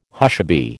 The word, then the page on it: hushaby: American English